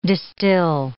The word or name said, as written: distil